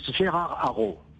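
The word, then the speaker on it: Gérard Araud: You know, really, it's Gerard Araud. – Gerard Araud.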